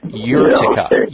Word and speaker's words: urtica: And last, the last remedy in the homeopathic category is something called uh, urtica uh, and I'll spell it U R T I C A urtica,